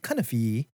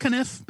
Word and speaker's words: Cunniffe: Cunifee. Cover. – Pretty but, sure it's just Cunniffe, but all right. – Yeah, but I like D. Cunifee because it rhymes.